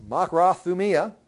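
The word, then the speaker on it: makrothumia (μακροθυμία): Yes, makrothumia.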